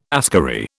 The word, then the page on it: askari: British English